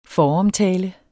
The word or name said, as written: foromtale